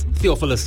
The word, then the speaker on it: Theophilus: Theophilus? – Theophilus, yeah.